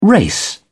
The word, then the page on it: race: British English